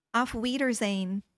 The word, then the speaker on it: Auf Wiedersehen: Auf Wiedersehen.